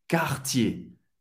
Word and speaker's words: Cartier: Duas sílabas, ER no final de uma palavra tem o som do E. Cartier, Cartier.